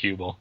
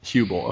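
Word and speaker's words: Huebel: And Rob Hubel, I think, is that how you say it? Hubel, the dude from... – I think it's just Hubel. – Hubel, okay.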